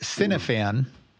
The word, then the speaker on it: Cynefan: Well, I'm now going to be on the Twitter account for my uh, Twitch channel, which is it's pronounced Cinefan but it's as in like cinema fan, but it's not spelled that way. It's spelled C Y N E F A N.